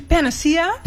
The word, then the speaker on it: Panacea: Panacea, zeg ik dat goed? – Ja, in het Engels zeg je Panacea.